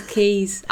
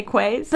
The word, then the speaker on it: quays: Oh, yeah, it is keys. Again, it's one of those words. – I know, it really is. – I have these words that I pronounce wrong. – But it's anytime you get down to, like, fucking the keys down in South London, you know, I always want to say quays.